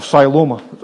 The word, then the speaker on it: Siloam: Then he anointed the man's eyes with the mud and he said to him, go and wash in the pool of Siloam.